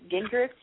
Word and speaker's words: Gingrich: There's a guy who's supporting Newt Gingrich in, am I saying that right? Gingrich, is that it, or is it Gingrich? – Yeah.